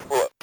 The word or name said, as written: put